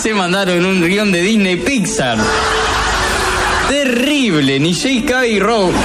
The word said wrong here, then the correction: J.K. Rowling: no lo sabía ni pronunciar, ni... Bruto, Dios mío. Ay, que siempre me olvido. J.K. Rowling, ni J.K. Rowling, escribió esto, loco.